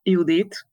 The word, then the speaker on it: Judit: My name is Gellér Judit.